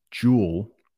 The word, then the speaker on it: jewelry: So if you can say jewel, which might not be easy, jewelry, jewelry, jewelry. It's almost like 2.5 syllables, 2.5 word parts, jewelry.